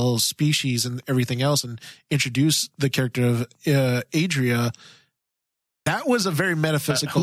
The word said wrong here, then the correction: Adira: Or the character of, I keep saying her name wrong. Adira.